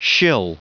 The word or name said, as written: shill